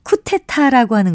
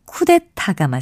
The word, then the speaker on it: 쿠데타: 흔히 쿠테타라고 하는 것은 쿠데타가 맞습니다. 이 경우에는 첫째 음절과 셋째 음절에 모두 거센 소리가 있어서 둘째 음절 역시 그 영향을 받아 쿠데타로 발음하게 되는 것이 아닌가 추측해 봅니다. 하지만 쿠데타가 맞는 표기이고 발음이라는 것 기억하시면 좋겠습니다.